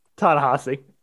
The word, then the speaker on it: Ta-Nehisi: I'm not going to say it wrong. Ta-Nehisi? – Ta-Nehisi. – Ta-Nehisi, close though. – It's not spelled that way, but it's pronounced that way.